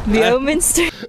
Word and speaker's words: Leominster: Leominster.